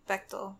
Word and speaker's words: Bechdel: Is it Bechdel or Bechdel? – Bechdel. – It is Bechdel. Okay, good. – Bechdel. – Bechdel. – Bechdel. Eat your vegetables. – Oh my God. – With Bechdel. Rhymes with rectal.